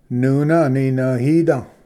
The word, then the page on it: ᏄᎾ ᎠᏂᏅᎯᏓ: nuna-aninvhida